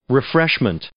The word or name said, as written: refreshments